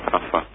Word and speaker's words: François: Su nombre es François, ¿no? Lo pronuncio bien, porque mi francés realmente no, es muy pobre. – No, está perfecto, François.